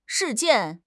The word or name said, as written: 事件